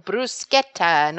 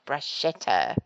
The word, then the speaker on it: bruschetta: For example, pistachio is pistacchio, mascarpone, mascarpone, and bruschetta, not bruschetta.